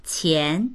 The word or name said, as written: qian2